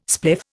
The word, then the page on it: spliff: Indian